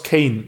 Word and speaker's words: CAYN: C-A-Y-N. Sprich, wie spricht man es aus? Kane. – Kane, oder? ja.